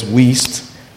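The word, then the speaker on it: Wuest: So I'd like us to get a verse of scripture also from Kenneth Wiest, spelled W-E-W-U-E-S-T, and pronounced as Wiest.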